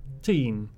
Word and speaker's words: Tignes: How do you actually pronounce that? – London to... Ooh, Teen? Tyne? – Tyne? – Teen. – Teen? – T-I-G-N-E-S for any spelling enthusiasts.